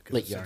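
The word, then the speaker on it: Littjara: Reflection of Lit-Jara. – Lit-Jara, it's a soft J.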